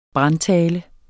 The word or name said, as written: brandtale